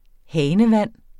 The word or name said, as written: hanevand